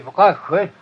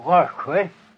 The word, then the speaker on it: Åsjö: Ja, det är stavat uttalat att det är Rösjö. Annars. – Vad kallar ni det? – Rösjö. – Jaha. – Men det stavas på Åsjö.